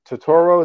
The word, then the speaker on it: Turturro: You got your boy, John Turturro.